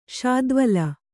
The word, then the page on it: ಶಾದ್ವಲ: śadvala